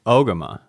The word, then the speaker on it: Ogema: Ogema. – Ogema. Okay. Just like I thought. Okay. – Needs an accent on the O.